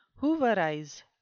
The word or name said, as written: hooverize